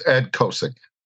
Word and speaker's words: Ed Kosiec: Could you please correct me, Ed? – It's Ed Kosiec.